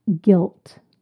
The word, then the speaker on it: gilt: Word number nine, guilt. Guilt. This is spelt G-I-L-T. It sounds the same as the word guilt, G-U-I-L-T, but this is a different word, different meaning.